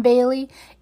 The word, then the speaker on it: Bailey: Again, I do get called Haley quite a bit, or people will drop the E in my name. It is spelled B-A-I-L-E-Y. And so I will tell people, oh, it's Bailey's, like Bailey's Irish Cream, which is a lovely drink that I enjoy very much.